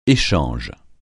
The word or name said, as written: échange